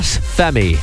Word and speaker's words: F3MII: If you check his Instagram at F3MII, how do you pronounce that? Femi.